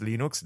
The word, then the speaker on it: Linux: And it was, "My name is Linus Torvalds, and I pronounce Linux, Linux."